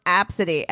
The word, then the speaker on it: Abcde: That woman who was just like, they made fun of my kid's name and it was like A, B, C, D, E. And she's like, it's pronounced Apsidy.